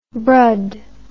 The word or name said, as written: brud